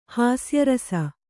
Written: ಹಾಸ್ಯರಸ

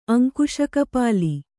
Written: ಅಂಕುಶಕಪಾಲಿ

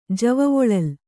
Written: ಜವವೊಳಲ್

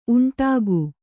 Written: ಉಂಡುಕ

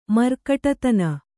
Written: ಮರ್ಕಟತನ

